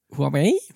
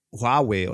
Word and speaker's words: Huawei: Das spricht man gar nicht Huawei aus im Übrigen, wusstest du das? – Huawei? – Nee, Huawei oder sowas. – Huawei.